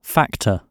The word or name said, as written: factor